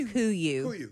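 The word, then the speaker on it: Kuiu: It's pronounced Kuyu. – Kuyu.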